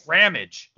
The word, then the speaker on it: Ramage: Ramage. Like damage.